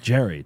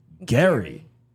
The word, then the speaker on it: Gerry: But apparently, and they wrote it on a sign, G-E-R-R-Y. Then they went around Hollywood Boulevard, Jimmy Kimmel's crew, and they were like, how do you pronounce his name? And they were like, Jerry, Jerry, Jerry. And then he's saying on the show it's pronounced Gary.